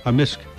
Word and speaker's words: amisk: Yeah, so how do you say beaver? – Beaver? Amisk. – Amisk. – Amisk. – So the K can be silent, but generally it's...